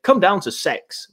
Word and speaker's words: CEX: It's basically across the road from sort of like the Boot and McDonald's in that sort of square, across the road from CEX, which apparently is pronounced sex, but I'm not going to say that.